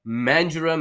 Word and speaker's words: Mandurah: I don't know if you knew that, Robbie, playing for the Mandurah Magic. Did I say that right? – You did say that right.